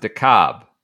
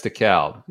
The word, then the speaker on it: DeKalb: I think in Georgia, they pronounce it DeKalb. – DeKalb. DeKalb. – But in Illinois, it's DeKalb.